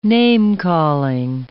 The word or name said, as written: name-calling